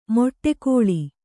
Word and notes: ಮಡುಗೊಳ್ಳು: maḍugoḷḷu

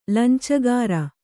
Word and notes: ಲಂಚಗಾಱ: lancagāṛa

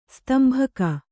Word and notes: ಸ್ತಂಭಕ: Kannada

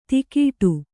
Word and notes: ಧಕ್ಕಡಿ: Kannada

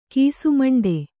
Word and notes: ಕೀಸುಮಂಡೆ: kīsumaṇḍe

